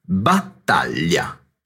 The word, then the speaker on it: battaglia: Inoltre, fai attenzione alla pronuncia della parola battaglia con il gruppo ll. La pronuncia corretta è battaglia.